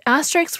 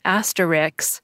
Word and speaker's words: asterisk: The right pronunciation is asterisk. You've probably heard it pronounced like asterix or asterisk, but it's asterisk.